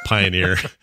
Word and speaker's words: Pioneer: Did you do anything for pee on her day? – No, we pee on her. – Pee on her day? – It's Pioneer. I like how you pronounce it, though. Pioneer and pee on her.